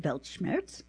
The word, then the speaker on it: Weltschmerz: Most of these words were invented recently, but one of them, Weltschmerz, pronounced Weltschmerz, a German term meaning world pain, has been around since the early 19th century.